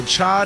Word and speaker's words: chai: National Chai Day. – Okay. – Did I say that right? – Yes, like chai lattes.